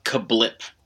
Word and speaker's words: CABLP: Criss Angel has a new restaurant, and it's spelled C-A-B-L-P. And Crabup. – Apparently it's Kablip.